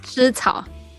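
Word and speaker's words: 蓍草: 哎，老师这个字怎么念？ – 湿草。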